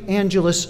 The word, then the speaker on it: Angelus: It's spelled like the word angel with a little difference at the end, A-N-G-E-L-U-S. That's again, A-N-G-E-L-U-S, pronounced Angelus. Should be your first hit on your Google search.